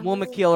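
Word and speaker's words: Mûmakil: It's called Mumakil. – The Olyphant. Yeah, the Mumakil or the Olyphants.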